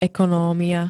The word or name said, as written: ekonomia